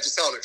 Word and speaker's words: Jeselnik: Is that Jeselnik? – Yeah, Jeselnik.